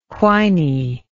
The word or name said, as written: whiny